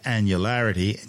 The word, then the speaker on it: annularity: The path of annularity, and yes, that's how it's said,